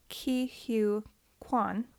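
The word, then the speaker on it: Ke Huy Quan: My last one is Ki-Hu Kwan. – Mm-hmm. – Did I say that right? – Yes.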